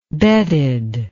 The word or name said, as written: betted